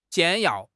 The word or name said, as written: 简要